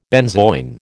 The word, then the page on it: benzoin: American English